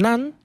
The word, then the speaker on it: naan: Sí, nosotros tenemos los panes tradicionales, que es el naan, que se escribe con N-A-A-N, que a veces la gente lo termina pronunciando con M, naan, pero es con N.